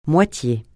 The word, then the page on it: moitié: francais